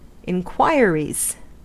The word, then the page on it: inquiries: American English